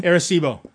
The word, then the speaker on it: Arecibo: Arecibo.